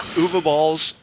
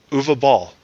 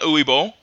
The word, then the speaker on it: Uwe Boll: No, that's how you pronounce it. – Wait, what did you say? – Uwe Boll. – Really? – Yeah. – Not Uwe Boll? – Nope. Learn German.